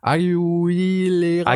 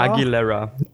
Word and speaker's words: Aguilera: This Christmas von Christina, ach je, Aguilera. – Aguilera. Ah, Aguilera. Ah, habe ich ja fast richtig ausgesprochen.